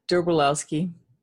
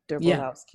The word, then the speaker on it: Derbolowsky: Derbolowsky. – Derbolowsky. Did I get it? – Derbolowsky.